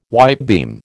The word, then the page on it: whitebeam: American English